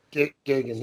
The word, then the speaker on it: Gagin: Gagin? – Gagin, yeah.